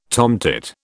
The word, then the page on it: tomtit: British English